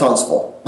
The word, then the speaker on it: Huntsville: I tend to say Huntsville because that's the way we spell it, but that is not the way we say it. – No, it's Huntsville. – Okay. – You're right. It's spelled Huntsville. I guess if you live there long enough, the T becomes kind of semi-silent. – Okay. And that V-I-L-L-E sounds like V-U-L to me.